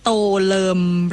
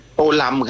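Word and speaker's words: Tô Lâm: โตเลิมหรือโตลัมคะโตลัม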